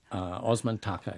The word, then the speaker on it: Osman Taka: It's Vale e Osman Takis, if I'm pronouncing that right. – Osman Taka, yeah.